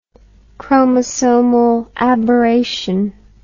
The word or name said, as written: chromosomal aberration